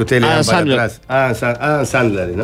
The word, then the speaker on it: Adam Sandler: Adam Sandler. Como los chicles Adams, pero sin la S.